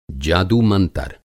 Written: जादू-मंतर

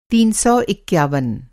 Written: तीन सौ इक्यावन

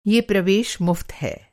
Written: मुफ़्त

